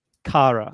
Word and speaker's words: Kara: It's like a car and it's just Kara.